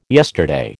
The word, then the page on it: yesterday: American English